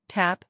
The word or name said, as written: Tap